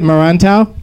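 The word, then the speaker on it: Merantau: And how do you pronounce it, Marantau? – That's actually exactly how you pronounce it.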